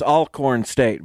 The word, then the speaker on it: Alcorn State: And I was told it's Alcorn State, by the way. So I'm going to start using that pronunciation.